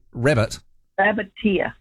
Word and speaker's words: Rabbiteer: Spell it, because it's not rabbit. Are you saying rabbit? – Rabbiteer.